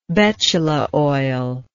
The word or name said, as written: betula oil